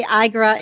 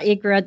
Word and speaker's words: IGRA: IGRA. Oh, is it really? – Yeah, it is. You can say IGRA, IGRA.